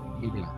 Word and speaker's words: Emilia: Emilia.